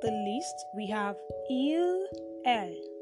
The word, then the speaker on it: elles: And then for a group of girls, we say E L L E S. Okay, which has been pronounced as what? L.